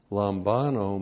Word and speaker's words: Lambano: Lambano. L-A-M-B-A-N, long O, Lambano.